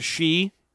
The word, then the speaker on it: sidhe: So you've seen this word before, S-I-D-H-E, pronounced she.